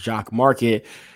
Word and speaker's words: Jock MKT: So Jock MKT, they like, you pronounce it as Jock Market. That's the way that they're looking at it.